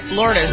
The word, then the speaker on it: Lourdes: Lourdes, yeah.